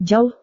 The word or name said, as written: jauh